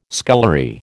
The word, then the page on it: scullery: American English